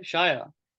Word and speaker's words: Shiyah: How do I say your name? Shia. – Yeah, you got it right. Well done for that.